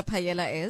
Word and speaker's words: paella: It's a Spanish word? – So the L is silent. It's Paella Day.